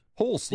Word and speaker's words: Hulce: Am I pronouncing this right? Hulce? – No, Hulce. – Hulce, yeah. Close. – That was close. – H-U-L-C-E. There's plenty of ways that could go.